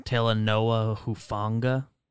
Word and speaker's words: Talanoa Hufanga: It is pronounced Talanoa Hufanga.